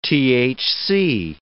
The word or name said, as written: THC